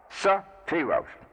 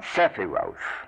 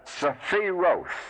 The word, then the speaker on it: Sephiroth: Sephiroth. It is not Sephiroth. The letter E in some of these Hebrew words, as in Sephiroth, Tephareth, etc., the E is not pronounced. Actually, the E could be left out very well. The E here is put in as an equivalent of a Hebrew vowel sound called the Shiva, which only means a silent phrase, a silent pause. So we have Sephiroth.